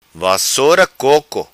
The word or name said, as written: vassora coco